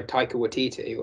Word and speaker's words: Taika Waititi: Similar to Get Out, Jojo Rabbit obviously was directed and made by Taika Waititi, or however you pronounce his name.